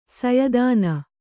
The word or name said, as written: ಸಯದಾನ